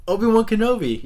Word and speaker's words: Obi-Wan Kenobi: Obi-Wan Kenobi? – Yes. Is that how you say it? I'm sorry. – What the f- You're the bigger Star Wars fan than I am! Obi-Wan Kenobi.